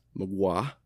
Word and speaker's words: Mgła: Pronounced Mgła.